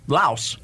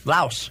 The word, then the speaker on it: Laos: Yeah, and then the other one is Laos. – Really? – Laos. – Okay. – You don't want any Laoses on you. – No, you don't. – L-A-O-S, four letters, one syllable. – And Chad. – And Chad in Africa. – C-H-A-D.